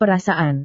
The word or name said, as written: perasaan